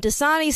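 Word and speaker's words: Dosani: And Dasani sounds like the water, but it's not DA, it's DO.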